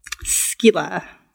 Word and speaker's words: Skylla: Like Kila, but with an S? – But it's spelled S-K-Y-L-L-A. – Skila. – Skila.